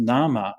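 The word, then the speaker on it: nāma: In fact, the English word name was actually pronounced nama up until a few hundred years ago. They had what they call the great vowel shift in English, and so now we pronounce it differently, but it was pronounced nama up until quite recently.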